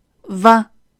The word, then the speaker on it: vingt: 我们首先需要注意的是，当二十后面跟上一到九之后，它原本不发音的这个 t 要开始发音。单独的二十是 vingt，但是二十四 vingt-quatre，二十五 vingt-cinq，vingt-cinq。